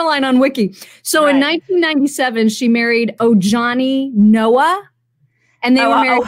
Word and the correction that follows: Ojani: Ohani. His name is Ohani. You don't pronounce the J. Or you do pronounce it, but you pronounce it as an H. Come on, don't you know? It's Ohani.